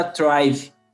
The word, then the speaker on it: Traive: Ele tem 39 anos, natural de Vitória, cidade maravilhosa, e cofundador da Thrive.